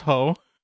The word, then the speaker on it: Hoh: So it's pronounced Ho. – As in slap the, yeah. – Yeah, H-O-H.